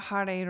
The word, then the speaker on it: Hare: and then we'll do the Hare Rama part tomorrow. So all we are saying today is Hare Krishna, and it's Hare, which is H-A-R-E, and it's, I pronounce it with a soft R, Hare Krishna.